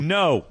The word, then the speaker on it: Gno: DJ No. – Yes, the G is silent. G-N-O.